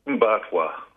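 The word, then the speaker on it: Mparntwe: How do you say it? – Mbatwa. – Mbatwa. – that's what the local Arinta people, that's the name of the place where Alice Springs is. – Yeah. – So it's pretty widely used. – Mbatwa. Is that how you say it? Say it again.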